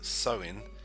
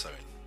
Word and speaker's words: Samhain: It's thought to have originated with the ancient Celtic festival of, pronounced Samhain, Samhain, okay, but pronounced Samhain, when people would light bonfires and wear costumes to ward off the roaming ghosts.